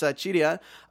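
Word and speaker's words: Qidian: Apologies again for mispronouncing Chidian the entire last episode. It's Chidian.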